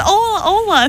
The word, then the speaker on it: hola: So, do they pronounce the H and... – No, they said "Ola Ola."